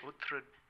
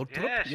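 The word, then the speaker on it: Uttrup: I just want to give a shout out to Anna Mears on the coverage for the way she pronounces Cecily Uhtred Ludwig. – With the roll, the little Utrup.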